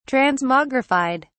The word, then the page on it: transmogrified: British English